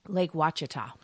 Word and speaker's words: Lake Ouachita: Lake Ouachita, but it's spelled O-U-A-C-H-I-T-A.